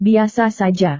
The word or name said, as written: biasa saja